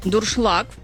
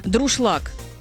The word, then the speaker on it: дуршлаг: Юля написала нам, что ее бесит, когда говорят неправильно слово «дуршлаг». «Дуршлаг» правильно, а многие произносят как коверка «друшлаг».